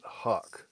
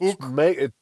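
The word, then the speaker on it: HUK: The clothing line, H-U-K, I've always called it Huck. – Yes. – Yeah, me too. – See, I was worried about the little line across the U. – That's Hook. We have been corrected Hook. From the people Hook. At Huck that the correct pronunciation is actually Hook.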